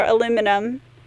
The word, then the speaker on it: aluminum: We do spell it differently in the States, therefore different pronunciation. There was no extra I at the end, therefore aluminum. Whereas here there is the extra I, so it's aluminium. The same is... – How do you spell yogurt? – Y-O-G-U-R-T, so it is spelled differently and pronounced differently.